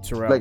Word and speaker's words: Terrell: Terrell.